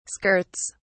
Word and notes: skirts: British English

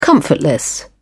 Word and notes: comfortless: British English

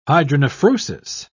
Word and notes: hydronephrosis: British English

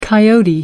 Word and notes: coyote: American English

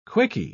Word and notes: quicky: British English

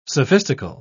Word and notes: sophistical: British English

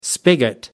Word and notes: spigot: American English

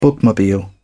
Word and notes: bookmobile: British English

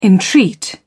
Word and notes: entreat: British English

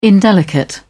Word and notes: indelicate: British English

